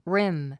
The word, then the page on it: rim: rím; リム